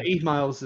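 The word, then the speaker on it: Eve Myles: So both Eve Miles, is it pronounced? – Eve Miles is Gwen, yes.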